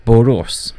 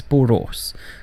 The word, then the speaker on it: Borås: I would probably pronounce that incorrectly. and I think it's pronounced as Borås. Yes, Borås. Because the A has an apostrophe like O above the A, which is O. So Borås.